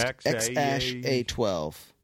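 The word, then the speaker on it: X Æ A-12: Is that always pronounced They say it's pronounced X-A-12. – What? – X-A-12. That's how you're supposed to say it. Ash, I guess. – Ash? – He identified the second character as Ash.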